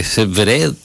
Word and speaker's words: Zverev: Que dicen que Zverev, así se pronuncia,